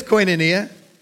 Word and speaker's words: koinonia: That's the word koinonia. It's spelled K-O-I-N-O-N-I-A, koinonia.